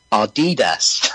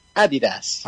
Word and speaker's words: Adidas: I'm looking at an article here on Business Insider UK saying, if you're an American, you're probably pronouncing the sneaker brand Adidas as Adidas. – Yep. – That's completely wrong. It's pronounced Adidas.